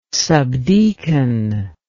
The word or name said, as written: subdeacon